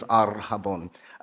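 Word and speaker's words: arhabon (ἀρραβών): It's arhabon. Arhabon. Now, David, do you roll your R's in Greek? It's A-R-R, so it's arhabon.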